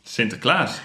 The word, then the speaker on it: Sinterklaas: And with Sinterklaas, it's, well, like any Dutch name, you put the emphasis or the accent on the last syllable.